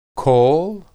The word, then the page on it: kol: hebrew